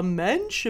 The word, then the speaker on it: mensch: To be a mensch. Am I saying that right? Yeah. And I was like, yeah, there's only like three phonemes in the whole word. Man. You got it.